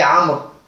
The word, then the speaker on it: Amr: Lorsqu'il n'y a pas les voyelles, on ne sait pas La différence entre Omar et Amr, ça s'écrit de la même manière. Aïn, Mim, Ra. Donc, les Arabes, depuis très longtemps, pour différencier les deux noms, après Amr, ils mettent un waw. Mais le waw, on ne le lit pas.